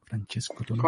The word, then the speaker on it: Francesco Tonucci: Y bueno, también es presidenta de la Asociación Pedagógica de Francesco Tonucci, no sé si lo pronuncio bien, Francesco Tonucci.